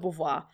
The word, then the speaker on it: Beauvoir: Simone de Beauvoir. – That's good, Beauvoir.